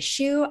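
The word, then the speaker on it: Xu: Shu is spelled X and U because Chinese people are really tricky like that, and...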